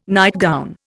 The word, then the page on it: nightgown: Indian